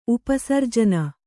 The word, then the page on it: ಉಪಸರ್ಜನ: upa sarjana